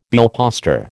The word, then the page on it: billposter: American English